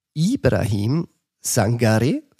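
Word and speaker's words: Ibrahim Sangaré: Ibrahim Sangare, richtig ausgesprochen? – Richtig ausgesprochen.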